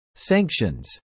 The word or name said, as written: sanctions